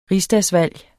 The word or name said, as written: rigsdagsvalg